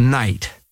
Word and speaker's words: Knight: The piece is called Night. Night spelled with a K, like night in shining armor.